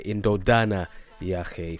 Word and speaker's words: Indodana Yahe: Indokana Yahe, or rather I should say Indodana Yahe from Nandifa 808.